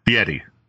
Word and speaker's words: Fieri: And Guy Fieri, if you're leaning into the pronunciation, it's actually more of a T sound, Fieri.